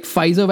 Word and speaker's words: Pfizer: The Pfizer, is that how you say it? Pfizer vaccine? – That's correct.